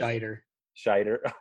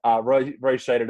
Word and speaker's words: Scheider: I think Roy Schneider or Snyder or whatever we pronounce it. – Scheider. – It's not even, there's no N in it. Okay. Roy Scheider does a great job.